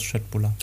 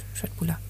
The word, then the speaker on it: Köttbullar: Ah, ah heißt es nicht Köttbullar? – Nein, heißt, also wenn du es äh, schwedisch okay. richtig aussprechen möchtest, dann heißt es Schöttbullar. – Ja, gut, Schöttbullar.